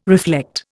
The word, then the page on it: reflect: Indian